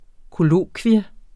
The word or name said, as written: kollokvium